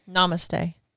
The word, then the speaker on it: namaste: How do you namaste.